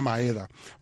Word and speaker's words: Maeda: No siempre he pronunciado ese apellido bien, Kenta Maeda.